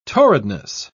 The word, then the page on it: torridness: British English